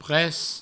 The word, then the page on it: pres: provençal